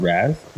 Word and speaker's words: Raz: Like, you know, like for you, for instance, you just brought up, it's pronounced Raz, you said? – Yeah, it's Raz Energy.